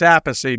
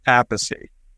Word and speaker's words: Appice: But my father said Appice. Carmine used to say Appice. So I'm Appice. – So we're sticking with Appice.